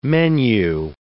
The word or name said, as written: menu